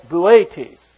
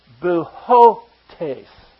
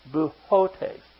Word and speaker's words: Boötes: I've had trouble pronouncing that name because I started out calling it Boötes. And that's not right. But if you ever said someone's name and you got used to it and found out it wasn't right, it's real hard to change it. But it should be pronounced Boötes.